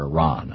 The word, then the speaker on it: Iran: Dick Cheney has long favored upping the threat of military action against Iran. or Iran, I guess is the correct pronunciation.